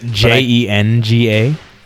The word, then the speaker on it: Jenga: Is it J E N G A? – I think it's a G. – A G? – Jenga. – A soft G, I guess.